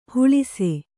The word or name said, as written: ಹೊಳೆಸು